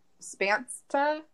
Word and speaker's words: Spantsa: And she was also given a nickname, and I think it's pronounced Spansta. It's S-P-A-N-T-S-A. I don't know what to do with the T-S. – Like, let's. – Okay. So, Spansta. – What was the spelling again? – S-P-A-N-T-S-A.